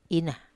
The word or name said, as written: ina